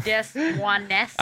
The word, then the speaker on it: Des Moines: I believe it's pronounced Des Moines.